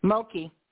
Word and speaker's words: Mulkey: Mumpkin is Munkley. Is that how do you pronounce that? – Mulkey. – Kim Mulkey.